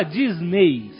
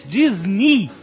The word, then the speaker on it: Disney: O EY no final da palavra de Disney Na verdade, não se fala Disney. Se fala em inglês, na pronúncia em inglês, Disney. Tem um som de I.